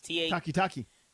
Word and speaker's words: Takitaki: I think his name is pronounced Takitaki. It's Takitaki. – T-A-K-I-T-A-K-I.